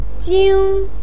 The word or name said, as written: jing1